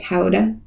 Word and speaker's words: Gouda: Some triple cream and I have been watching how Gouda is made. Mm. And over there, it's pronounced Howda, which is fun.